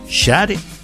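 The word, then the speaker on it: Sade: Créame que en lo personal he escuchado muchísimas maneras de pronunciar este nombre, pero tuvimos que buscar en internet un registro visual donde la propia Shade nos entregara la forma de pronunciar su nombre artístico. Así que según la propia Shade, se pronuncia así su nombre.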